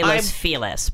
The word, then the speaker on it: Los Feliz: But it's Los Feliz, right? – I know, they say Los Feliz, but it's spelled Los Feliz.